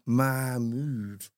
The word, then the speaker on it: Mahmood: Oh, it took me 10 times to perfect how to the pronunciation of Mahmood. Do the big M-A, Mahmood, or the short M-A and the big...